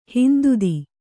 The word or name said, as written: ಹಿಂದುದಿ